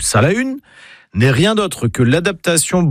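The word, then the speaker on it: Salaün: En breton, on prononce Salahun. Pas Salin, mais Salahun.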